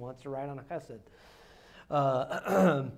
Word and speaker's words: chesed: It's the Hebrew word chesed. Okay? now, you might have to hold your hand up like this to, to say it correctly so that you won't uh, disturb your neighbor, but uh, it's the Hebrew word chesed.